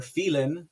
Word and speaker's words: Phelan: This here statement from President Trump addresses a very specific member of the Texas House, and that is Speaker Dade Phelan, or Phelan. Sorry, I pronounce it like an Hispanic.